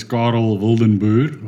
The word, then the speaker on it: Carel Wildenboer: Well, the the proper pronunciation is Carel Wildenboer, oh.